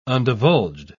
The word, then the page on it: undivulged: British English